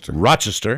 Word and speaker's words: Rochester: Excuse me, I should pronounce it correctly. – Rochester. Right.